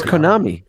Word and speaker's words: Konami: It's Konami.